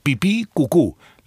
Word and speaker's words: pipí cucú: así nomás, pipí con acento en la segunda I, cucú con acento en la segunda U, pipí cucú.